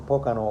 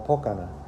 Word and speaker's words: Pocono: Se escribe Pocono, le dicen Pocano o Pocana en inglés y se escribe Pocono.